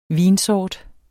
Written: vinsort

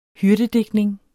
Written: hyrdedigtning

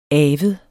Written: avet

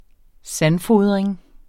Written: sandfodring

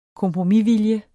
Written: kompromisvilje

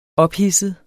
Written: ophidset

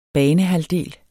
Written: banehalvdel

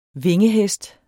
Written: vingehest

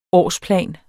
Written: årsplan

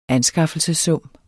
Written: anskaffelsessum

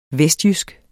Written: vestjysk

